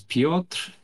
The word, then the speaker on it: Piotr: It was Piotr Chudobinsky. – Better than Farmer. – You say it like you're from Spain. – That's true. – Did I? – It sounds very Polish. – It's Piotr.